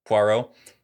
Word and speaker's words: Poirot: I just gotta say to that point, like when we first see, is it Poirot? How does he pronounce it? – Poirot.